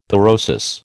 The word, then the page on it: pyrosis: American English